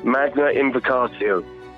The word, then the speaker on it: Magna Invocatio: Correct me if I'm saying this wrong, because it is Latin. Magna Invocatio? – Magna Invocatio. Yes, that's right.